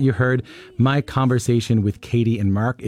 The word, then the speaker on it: Caity: Katie is spelled C-A-I-T-Y, and George is spelled G-Y-O-R-G-Y.